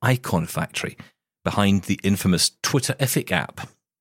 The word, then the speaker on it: Twitterific: You got to say it like your Scooby-Doo, Twitterific. – Terrific, yeah.